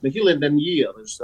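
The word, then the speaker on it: Na h-Eileanan an Iar: He's been MP for since 2005 for the Western Isles, Nihil and Nyir, as you pronounce it, of course.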